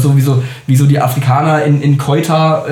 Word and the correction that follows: Ceuta: ähm, äh, oder wie man es richtig ausspricht, war bestimmt falsch ausgesprochen, also in diesem spanischen, in dieser spanischen Enklave in Nordafrika, Ceuta, ja, danke, mhm.